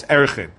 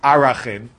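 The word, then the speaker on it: Arakhin: If you went to a modern Orthodox school, if you went to yeshiva school, it's Erechin. If you went to a modern Orthodox school, you know how to pronounce things the right way, it's Arachin.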